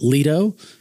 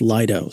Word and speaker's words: Lido: The last show we did where we talked about staking, given that I had a house in Sarasota for so long, I kept pronouncing it Lido. Because of Lido Key, apparently it's Lido.